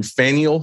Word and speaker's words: Faneuil: I went to Faneuil Hall, which I finally figured out how to pronounce.